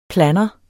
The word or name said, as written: planner